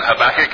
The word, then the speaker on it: Habakkuk: His name is Habakkuk. It's spelled H-A-B-A-K-K-U-K.